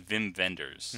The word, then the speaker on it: Wim Wenders: but certainly has a cult following in Wim Wenders. – Mm. I was going to say this. – You can see how pretentious I'm being by pronouncing his name in the proper Germanic way.